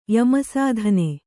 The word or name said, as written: ಯಮಸಾಧನೆü